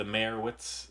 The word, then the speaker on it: Meyerowitz: They didn't really accentuate the O. It wasn't like Meyerowitz. They just said Meyerowitz.